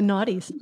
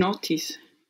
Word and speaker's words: noughties: The noughties. – What, how do you say that? – The noughties. – The noughties. Yeah. – Like not, I guess not. From zero. Yeah.